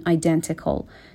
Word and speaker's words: identical: این تی رو هم معمولا توی تلفظ آمریکایی نمیخونن، وقتی تی بین ان و یک حرف صدادار قرار میگیره معمولا خونده نمیشه، میتونین هم بگین identical، درسته.